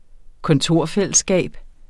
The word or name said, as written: kontorfællesskab